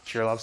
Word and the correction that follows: Krylov: subspaces, Krylov, I always mispronounce things,